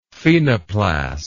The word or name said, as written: phenoplast